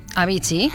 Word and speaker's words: Avicii: Bueno, pues, ¿cómo le llamas tú? – Avicii. Y yo diría que sí, que se pronuncia así.